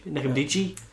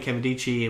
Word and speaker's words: Nkemdiche: Well, he was implicated in the event associated with the defensive line prospect Nkemdiche. – Silent N. No, it's never a silent N.